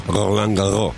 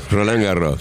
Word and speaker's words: Roland Garros: Roland Garros, si no sabes pronunciar, lo lamento, yo sé pronunciar bien. Esto se pronuncia. ¿No es cierto, Mariano? – ¿Cómo va, equipo? ¿Todo bien por ahí? Así, está muy bien pronunciado.